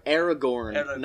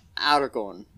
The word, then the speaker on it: Aragorn: It's Aragorn, not Aragorn.